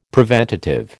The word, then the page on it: preventative: American English